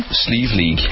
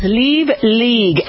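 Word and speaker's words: Slieve League: how do you pronounce it again? – Sleeve League. – Sleeve League, and sleeve means mountain.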